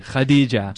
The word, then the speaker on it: Khadija: You know, is Khadija, how do you say Khadija? Even, you know, it's with a kha, like Khadija. – Jewish people and Muslim people or Arabic people can say it, the kha.